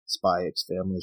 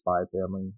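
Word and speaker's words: Spy × Family: and it talked about if you pronounce the X or not. And it actually did oh, yes. very good, not a thesis, but it did like like the history of pronunciation and why X's are present in some titles. So it's not Spy X Family, it's just Spy Family.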